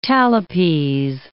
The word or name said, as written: talipes